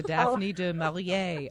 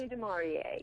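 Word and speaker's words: Daphne du Maurier: It's the Daphne de Maurier Award for excellence. – Yeah, Daphne de Maurier.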